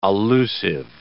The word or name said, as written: allusive